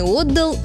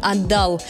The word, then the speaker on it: отдал: А попробуйте-ка его проспрягать. Он что сделал? Отвечаем, он и отдал, и отдал.